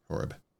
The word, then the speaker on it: Horeb: Horeb.